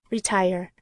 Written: retire

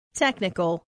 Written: technical

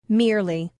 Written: merely

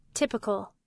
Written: typical

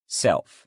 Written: self

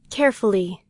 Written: carefully

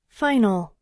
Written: final

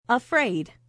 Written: afraid

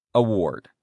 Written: award

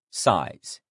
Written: size

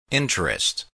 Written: interest

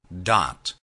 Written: dot

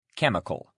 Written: chemical

